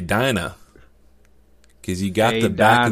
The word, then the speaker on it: Dinah: You just taught me, that I can actually pronounce it Dina instead of Dinah.